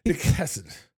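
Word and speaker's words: hesed (חסד): Well, you said hesed. – Hesed. – Oh, okay. That's a Hebrew word. – It's actually hesed.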